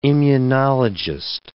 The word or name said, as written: immunologist